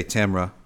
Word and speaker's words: Tamara: And you have to say Tamara, this is the right way to say it. Make sure you say it right.